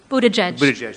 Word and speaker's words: Buttigieg: One of the candidates for president is Mayor Pete, I can't pronounce his name, Buttigieg. – Buttigieg, thank you very much.